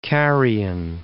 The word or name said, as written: Carian